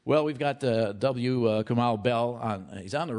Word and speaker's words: Kumail: It's Kumail. – Kumail.